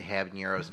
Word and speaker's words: habanero: One of them's habanero lime. – Gross. – I feel like I mispronounced the word habanero. God, could you sound more white? Habanero.